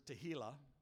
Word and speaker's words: tehillah: The word is tehillah, which rhymes with the word tequila.